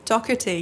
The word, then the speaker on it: Doherty: Is it Doherty? Doherty. – Doherty, yeah. It sounds like a K.